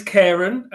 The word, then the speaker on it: Cairon: It is Cairon.